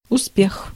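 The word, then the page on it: успех: IPA: /ʊsˈpʲex/; Russian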